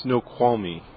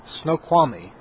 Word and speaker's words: Snoqualmie: Snoqualmie. – Snoqualmie. Okay, Snoqualmie.